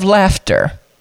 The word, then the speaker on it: laughter: But slaughter, S-L-A-U-G-H-T-E-R. How and why is that? But what's even more interesting, if you take the S off of slaughter, you have laughter. Yeah, L-A-U-G-H-T-E-R. Now the G-H is pronounced as F, and A-U is not pronounced ah, but it's pronounced at.